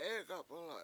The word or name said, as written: Egap1la7